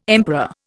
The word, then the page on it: emperor: Indian